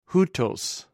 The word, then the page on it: houtws: greek